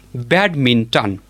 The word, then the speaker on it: badminton: It is pronounced as badminton with the stress on the first syllable.